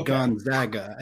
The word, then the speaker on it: Gonzaga: Gonzaga.